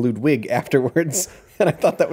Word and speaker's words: Ludwig: I believe he says it's pronounced Ludwig. – They have a discussion. My memory is that they decided that it was pronounced Ludwig. – I remember Ludwig himself saying Ludwig.